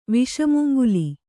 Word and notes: ವಿಷಮುಂಗುಲಿ: viṣa mungal

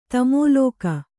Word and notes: ತಾಮಲಕಿ: tāmalaki

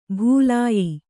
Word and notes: ಭೂಲಾಯಿ: Kannada; bhūlāyi

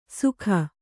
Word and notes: ಸುಕ: kannada

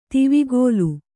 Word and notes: ದೇವಕುಲ: Kannada

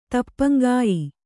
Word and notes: ತಪ್ಪಂಗಾಯಿ: tappaŋgāyi

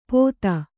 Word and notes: ಪೊತ್ತ: Kannada

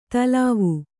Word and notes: ತಿಲ್ವ: tilva; kannada

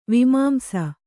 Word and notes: ವಿಮಾಂಸ: kannada

